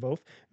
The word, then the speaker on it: Voth: I'm sorry, I mispronounced it. Voth. V-O-T-H.